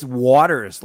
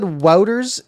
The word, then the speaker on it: Wouters: Guess what it's actually pronounced? – Is it actually Wouters? – It's Waters. Like. – Okay. Here's the thing. So yeah, I said Wouters in my rookie camp video, and then yeah.